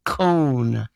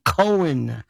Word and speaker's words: Cohn: It was, of course, Roy Cohn. And by the way, he's one of the most incredibly mispronounced name. They call him Cohen.